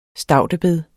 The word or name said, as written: staudebed